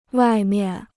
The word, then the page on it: 外面: wài miàn